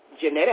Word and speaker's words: Jeanette: Jeanette Abney, Last name is spelled A, B, N, E, Y, and Jeanette is J-E-A-N-E-T-T-E.